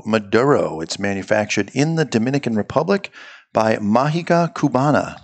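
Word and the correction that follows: Magia: Mahia. Magic. Cuban magic is the translation. So yeah, Mahia.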